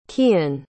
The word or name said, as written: cian